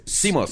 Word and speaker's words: Seamus: Pues a propósito de Samus, ¿cómo es? – Seamus. – Seamus.